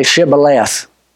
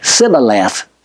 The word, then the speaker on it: Shibboleth: The problem was they had an impediment of speech that they could not pronounce the H in Shibboleth. And so they'd say Sibboleth, Sibboleth.